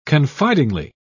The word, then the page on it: confidingly: British English